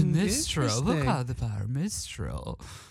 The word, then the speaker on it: Mistral: It's spelled Mistral. And I thought it was Mistrial at first, but it's Mistral.